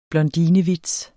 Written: blondinevits

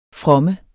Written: fromme

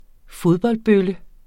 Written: fodboldbølle

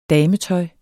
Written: dametøj

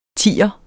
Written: tier

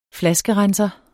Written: flaskerenser

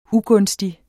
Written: UGUNSTIGE

